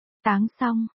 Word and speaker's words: Táng Song: Chú thích, 1, anh họ trong điện thoại anh Doãn là Tang Song đọc là, Táng Song, 2, Đường Trung này là Tang Châu nghĩa là bình đựng đường.